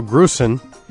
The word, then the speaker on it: Grusin: Grusin with Dancing in the Township.